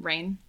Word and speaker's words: Raen: It's, I don't even know if I'm saying it right, Rain. R-A-E-N. Yeah, I would say Rain.